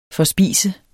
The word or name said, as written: FORSPISTES